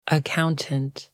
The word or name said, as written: accountant